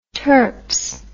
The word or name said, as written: terps